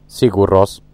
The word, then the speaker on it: Sigur Rós: Anzi, scusa, io amo i Sigur Rós, perché così si pronuncia.